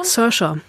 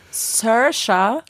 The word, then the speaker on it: Saoirse: Wie nochmal? Ausgesprochen? – Sersha. – Sersha. Genau. Es ist auch irgendein gälischer, nordirischer, schottischer Name.